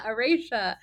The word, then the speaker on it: Aurasia: I pronounced that correctly. I practiced. I did. I listened to you say it. I was like, Aurasia. – Oh, my stars. Okay, yes, he's from Aurasia, which is the capital of the Tirandor province, which is in Navarre, obviously.